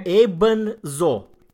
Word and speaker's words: Ebenso: Se scrie Ebenso. E-B-E-N-S-O. Un singur cuvânt. Ebenso. Da? – Am înțeles. – Și pronunția austriacă e... – Imso, da. Imso.